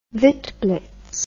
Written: witblits

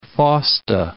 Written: Fausta

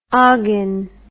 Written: oggin

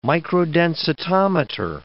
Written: microdensitometer

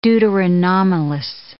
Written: deuteranomalous

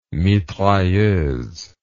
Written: mitrailleuse

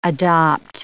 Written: adopt

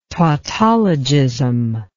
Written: tautologism

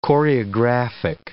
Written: choreographic

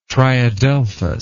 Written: triadelphous

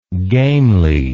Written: gamely

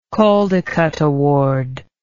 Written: Caldecott Award

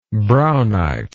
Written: braunite

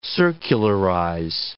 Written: circularize

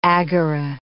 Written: agora

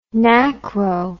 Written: NACRO

